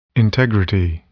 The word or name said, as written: integrity